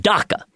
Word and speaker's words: DACA: DACA. It rhymes with caca. That's how you can remember that one.